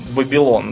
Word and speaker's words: Вавилон: Мы, например, говорим Вавилон, хотя правильнее Бабилон, да? Угу. Если посмотреть на произношение э, семито-хамитское и так далее.